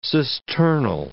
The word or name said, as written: cisternal